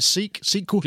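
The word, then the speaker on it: Seque: How would you say it? Sequel? – Beautiful.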